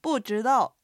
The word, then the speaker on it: 不知道: 不知道の不は四声です。